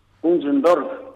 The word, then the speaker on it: Unsdorf: Se menciona entonces la sede Asociación Alemana, ¿se dice Unsdorf o cómo acostumbran ustedes decirlo? – Unsdorf. – Ahí está. – Como se pronuncia. – Ahí está. Perfecto. Por eso es como ustedes acostumbren, Unsdorf, ¿así? – Exactamente.